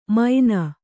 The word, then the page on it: ಮೈನ: Kannada